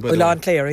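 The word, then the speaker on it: Oileán Chléire: Ilan Clara. Am I saying that correctly? – Ilan Clara, yeah.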